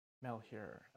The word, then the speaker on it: Malheur: Did I pronounce the name of that park right? Is my first question. – Malheur. – Okay. – Yeah, it's sort of weird because it's French, and so it should be like Malheur.